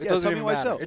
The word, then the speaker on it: Wiseau: Oh, is that how you say his name, Wiseau? – Tommy Wiseau.